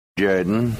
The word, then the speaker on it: Jordan: It's pronounced Jordan.